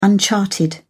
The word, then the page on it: uncharted: British English